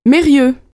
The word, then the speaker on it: Mérieux: Mérieux. – Comment ça s'écrit ? – M-E-R-I-E-U-X.